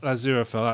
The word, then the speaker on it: Aziraphale: Aziraphale, that's it.